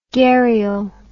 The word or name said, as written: garial